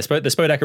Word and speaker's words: Spodek: That being said, I went to the Spodek. Is that how you pronounce it? The Spodek Arena? – Yeah, the Spodek Arena in Katowice.